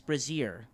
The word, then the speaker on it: brazier: I think you're mispronouncing that. – it's pronounced brazier. – Brazier. – There's a brass bra just in the middle. – Wait, what did I say? – You said brazier. – It's brazier.